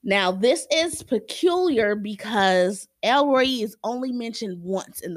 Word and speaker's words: El Roi: That is E L R O I.